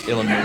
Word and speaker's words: Illinois: The S is silent. – Illinois.